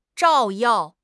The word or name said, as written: 照耀